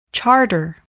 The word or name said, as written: charter